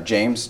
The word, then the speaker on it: James: James, J-A-M-E-S, last name Zaborvan, Z-B.